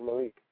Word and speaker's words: Malik: My first name is Malik. – How do you say your name? – M-A-L-I-K, Malik.